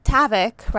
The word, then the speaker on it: Tavik: You know, Gala and Tavik rhymes with havoc.